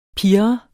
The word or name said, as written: pirre